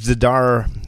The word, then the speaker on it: Zdar: Uh, and uh, Philippe Zdar.